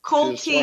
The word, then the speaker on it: Coltey: And I'm going to first of all tell you how to pronounce my name. – Thank you. – My name is Coltey. – Coltey. – Like it says, right.